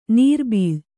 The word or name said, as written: ನೀರ್ಬೀಳು